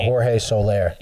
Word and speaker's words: Jorge Soler: It was George Soler. – Jorge Soler. – Yes. – I think is how you pronounce it.